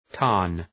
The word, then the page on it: tarn: tɑ:rn